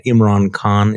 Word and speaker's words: Imran Khan: Imran Khan, is that how you pronounce his name? – Yes.